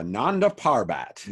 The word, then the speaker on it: Nanda Parbat: Let's head on back to Nanda Parbat. Is that what it's called? – Yes. – Did I say that right? – Yes, you did.